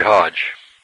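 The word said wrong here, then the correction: Hoge: He was a close friend of the family, or Hogue, I guess it's pronounced actually, Hogue took an active interest in Ewell's spiritual welfare, visiting frequently during his convalescence.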